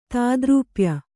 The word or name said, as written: ತಾದ್ರೂಪ್ಯ